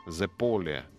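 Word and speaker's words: zeppole: Зеполе как итальянцы говорят, рикотта, зе поле, вот, зе поле, наверное, с сыром рикотта.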